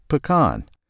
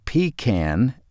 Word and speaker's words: pecan: A good sign of whether the slice in front of you is fit to eat is how the person making it pronounces pecan. If they say pecan and not pecan, you might want to ask for chocolate pie or cobbler instead.